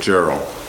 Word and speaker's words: Jarrell: spelled J-A-R-R-E-L-L. I tend to like to pronounce it Gerald, because then it rhymes with Farrell, and I may do that from time to time today, but I think the correct pronunciation is Jarrell.